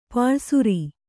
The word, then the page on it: ಪಲ್ಲಿಸರ: palli sara